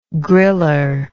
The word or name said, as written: griller